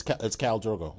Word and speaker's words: Khal Drogo: But Khal Drogo, and I don't even know if I'm saying his name right. – It's Khal Drogo.